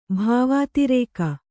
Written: ಭಾವಾತಿರೇಕ

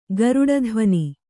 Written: ಗರುಡಧ್ವನಿ

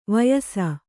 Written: ವಯಸ್ಸು

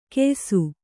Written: ಗೆಯ್ಸು

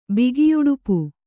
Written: ಬಿಗಿಯುಡುಪು

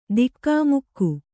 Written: ಧಕ್ಕೆಮುಕ್ಕೆ

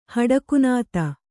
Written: ಹಡಕುನಾತ

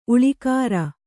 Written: ಉಳಿಕಾರ

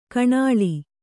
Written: ಕಣಾಳಿ

